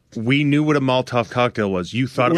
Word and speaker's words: Molotov: Why can't I say that? Molotov, right? – You are saying it right.